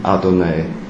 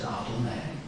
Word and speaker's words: Adonai: Das heißt, es steht zwar drin im Text Jahwe, aber sie sprechen Adonai.